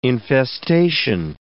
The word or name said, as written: infestation